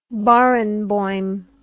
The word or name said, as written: barenboim